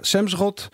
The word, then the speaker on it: Semsrott: Dus ja, toen kreeg je de groene europarlementariër Semsrott. Zeg ik dat goed? Ja, Semsrott.